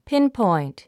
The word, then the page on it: pinpoint: pínpɔ̀int; ピンポイント